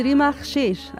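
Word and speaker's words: Rima Khcheich: Sí, Rima Shesh, así se pronuncia.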